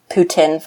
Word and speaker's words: poutine: We started out at the beer and poutine festival. P.S. That's how it's pronounced. – Wait, really? It's not poutine? Yeah, nope, it's poutine.